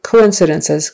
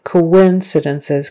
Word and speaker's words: coincidences: But that O-O, there's more of a like a W sound in the middle to join the O-O to the I. Coincidences, coincidences, coincidences. The syllable stress is on the second syllable.